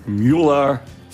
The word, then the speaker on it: Mueller: Müller, amerikanisch ausgesprochen, aber mit Vornamen weiß ich nicht. – Robert Swan Mueller, der Dritte.